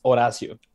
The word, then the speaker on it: Horacio: So my name is pronounced Horacio. In Spanish, the H is actually silent. Mm-hmm. So that's why everybody's like saying Horatio.